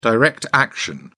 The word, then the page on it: direct action: British English